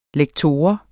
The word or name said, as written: lektor